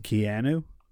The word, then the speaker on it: Keanu: It's pronounced Keanu.